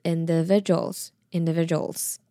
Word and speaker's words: individuals: 然后最后 D-U-A-L-S 那个部分呢，它的 D 可以比较想成是 J 的发音，它是 Jules Jules，所以连在一起就是 Individuals Individuals。